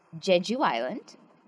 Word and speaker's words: Jeju Island: This book is about Jeju Island.